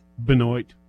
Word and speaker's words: Benoit: Now, is that pronounced Benoit or Benoit? – Benoit. – Mm-hmm. See, in the rest of the world, that word is Benoit. Yeah. – It's like beignet.